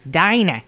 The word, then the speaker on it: Dinah: Is it Dina or Dana? How do you say Dana in Canadian? Dana? – No, it would be Dinah. – Dinah.